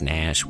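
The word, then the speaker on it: Gnash: His stage name is Nash, stylized as Nash with a silent G.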